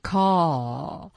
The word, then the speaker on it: call: call 还是那个 L 音也没有掌握好 call 啊，是舌尖要顶到这个上颚上啊，我们的这个发音课上也专门讲过这个音的啊，所以这些细节要注意下来再练习。